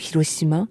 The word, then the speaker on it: Hiroshima: En fait, Hiroshima et Nagasaki, quand je prononce le mot Hiroshima, je pense pas forcément à la catastrophe. Mais quand je prononce, quand j'entends le mot Hiroshima à la française, sans H, le mot garde plus la marque de catastrophe, parce que en France, on ne prononce ce mot que pour évoquer la catastrophe.